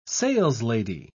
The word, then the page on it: saleslady: British English